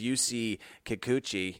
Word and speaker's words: Kikuchi: He's having a career year here versus Yusei Kikuchi.